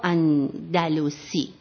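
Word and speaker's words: اندلس: ابوالقاسم یا ابوالحسن محمد ابن هانی اندلوسی یا اندلوسی، یعنی خودشون پشت اون دیوان اندلوس نوشتن، پس دو تا تلفظ، ما دیگه تو ایران اندلوس تلفظ میکنیم با زمه.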